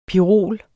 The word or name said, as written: pirol